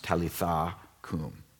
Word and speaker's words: Talitha kum: It's pronounced Talitha kum, "Little girl, I say, get up."